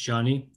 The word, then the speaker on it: Joni: Okay, Perry informs me that it's not Joni, it's pronounced Johnny.